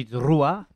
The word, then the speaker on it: Drua: Yeah, Fiji Drua.